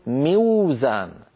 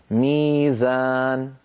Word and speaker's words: ميزان: وزن، فالحرف الأول فيها واو، فعندما نشتق من وزن مفعال لابد أن نقول ميوزان. ميوزان، لكن العرب لا ينطقون واوا ساكنة قبلها مكسور، لذلك يقلبون هذه الواو من أجل الكسرة التي قبلها إلى ياء فيقولون ميزان.